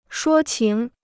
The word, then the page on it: 说情: shuō qíng